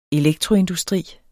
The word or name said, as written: elektroindustri